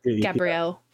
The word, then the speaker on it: Gabrielle: I don't know how you say it, but yeah, Gabrielli, maybe? – How's it spelled? G-A-B-R-I-E-L-L-E? – Yeah. – Gabrielle. – Gabrielle, right.